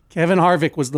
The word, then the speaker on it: Harvick: Kevin Harvick.